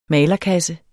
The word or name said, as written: malerkasse